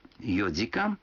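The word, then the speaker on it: йодикан (四時間): Некоторые цифры в этих случаях произносятся не так, как обычно. 4 часа – это йодикан.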